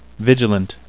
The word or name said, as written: vigilant